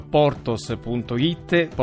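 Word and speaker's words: Porthos: Ecco, allora se volete conoscere da vicino le storie di tutti questi produttori andate sul sito portos.it, Portos si scrive con TH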